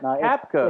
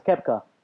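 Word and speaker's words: Koepka: You pronounce it Capcott. – No, it's Capka. – It's Kepka.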